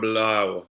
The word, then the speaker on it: Bulaga: And even I think I put too many syllables in there. He really gets Brian Bulaga, Iowa into about 2.5 syllables. – Brian Bulaga.